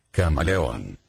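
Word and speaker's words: camaleón: Camaleon.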